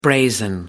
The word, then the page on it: brazen: American English